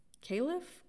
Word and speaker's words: Kalef: The Secret Life of Babies, you guys can YouTube Mia, I think she pronounces it Kalef, K-A-L-E-F.